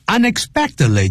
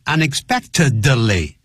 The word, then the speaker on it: unexpectedly: 感冒尤其是流感呢是可以传染的。Contagious, C-O-N-T-A-G-I-O-U-S. 所以这个是要特别特别记住。好 ,unexpectedly 就是意想不到的。I found a secret about Dad unexpectedly. 不要念成 unexpectedly. Unexpected, 那个 D, 停顿。Unexpectedly, 就像 friendly, he's very friendly with us.